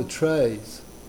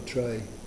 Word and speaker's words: trait: They can tell the traits or the trait. How do you say that here in America? A trait or tray? The personality of the person, the character. – Trait. Trait. Trait. Trait. trait. Yep. Okay.